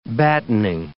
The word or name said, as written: battening